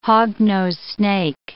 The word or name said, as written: hognose snake